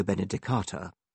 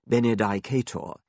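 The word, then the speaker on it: benedicatur: As sub-warden, I had to preside over dinner in hall and say grace before Benedictus Benedicat and after Benedicto Benedicata. I was one of the majority who pronounced this last word Benedicata. Some of the older, classically trained fellows pronounced it Benedicator, which fascinated me, although I never dared follow them.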